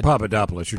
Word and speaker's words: Papadopoulos: Papadopoulos, you're dropping a syllable.